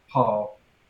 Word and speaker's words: Paugh: In 1980, Ramsey marries Patricia Ann, uh, something with a P, uh, Paw. I, I want to say Pog, but I know that's not how it's pronounced. – Oh, spell it in the chat. – A uh, P A U G H. – Paul. – Okay, that.